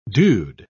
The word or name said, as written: dude